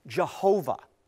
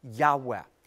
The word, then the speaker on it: Yahweh: Now, today, we hear different songs that are calling God Jehovah. And this is a mispronunciation of God's holy sacred name, a breathy Yahweh.